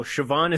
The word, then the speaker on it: Siobhan: No, Siobhan is spelled S-I-O-B-H-A-N.